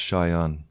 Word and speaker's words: Shayan: It's pronounced Shayan. It's a little different. – Shayan. Perfect. How do you spell that? – S-H-A-Y-A-N.